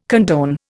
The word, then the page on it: condone: Indian